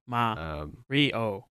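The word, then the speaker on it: Ma Rio: And the name is Mario, okay? – Yeah, that's sorry. – That's my new rap name, bro. It's M-A space R-I-O. Mario. I don't know why I say it like a robot.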